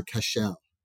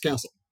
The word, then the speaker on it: Kascel: You know, if you look at it phonetically, you're going to, my old boss as a joke used to call me Mr. Kascel because he was a Serbian who spent a lot of time in Italy. And he said, phonetically, your name to me is Kascel. – Yeah. – I said, I don't care. It's pronounced Castle.